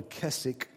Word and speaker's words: Keswick: Keswick Movement. That's spelled K-E-S-W-I-C-K. You don't say the W. Your English friends will correct you if you do.